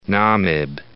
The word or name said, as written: Namib